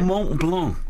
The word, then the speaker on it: Mont Blanc: Mont Blanc.